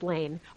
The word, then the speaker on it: Lahn: Is that how it's, well, it looks like Lahn. It's pronounced Lane. – Okay. – It's L-A-H-N, but it's okay. Pronounced like Lane.